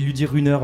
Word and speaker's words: Ludy Runner: Comment ça se prononce déjà exactement? Parce que je dis Ludy Runner, mais c'est peut-être... – Plutôt à l'anglaise, Ludy Runner. – Runner, voilà.